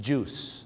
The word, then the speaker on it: juice: juice นะครับผม juice ไม่ได้ออกเสียงว่าจุ้ยนะฮะ